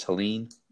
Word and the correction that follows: Helaire: Clyde Edwards Helaire, the H is silent.